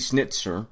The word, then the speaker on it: Schnitzer: AC Schnitzer, yes, that's how it's said, pumps up the BMW M2 to 420 horsepower, or near enough, M4 territory.